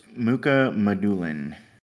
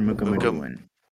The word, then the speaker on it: Mukhamadullin: I, I believe it's Mukhamadullin. Shakir Mukhamadullin. Shakir Mukhamadullin, okay.